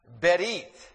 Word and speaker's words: Berith: It is the Hebrew word that is pronounced Berith, spelled B-E-R-I-T-H, Berith.